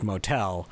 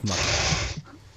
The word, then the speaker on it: Motel: Like, we're starting with the name is not pronounced Motel, it's pronounced Muttle.